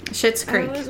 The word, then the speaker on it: Schitt's Creek: John Hemphill, who played Benny, and Eugene Levy, who played Lomax, went on to act together in, how do you say it? – Schitt's Creek. – It is Schitt's Creek? Okay.